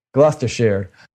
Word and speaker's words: Gloucestershire: Gloucestershire. In Gloucester, which is in Gloucestershire. Shire, not sheer.